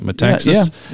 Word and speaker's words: Metaxas: Am I saying that right? Metaxas? – Yeah.